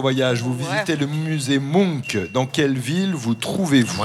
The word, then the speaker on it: Munch: Ça s'écrit M-U-N-C-H et je crois qu'on prononce Munk.